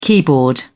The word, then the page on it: keyboard: British English